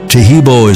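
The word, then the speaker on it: Taheebo: Tahibo is spelled T-A-H-E-E-B, like boy.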